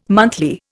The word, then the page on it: monthly: Indian